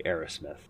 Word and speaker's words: Arasmith: I talked to Major Arrowsmith, A-R-A-S-M-I-T-H, just in case I'm pronouncing it poorly, Arrowsmith. Not Aerosmith, that's totally different.